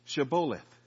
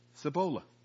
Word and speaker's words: Shibboleth: When they said no, they said to him, then say Shibboleth. And he said, Cibola. For he could not pronounce it right.